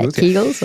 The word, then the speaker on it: Kegels: Yeah, Kegels, right.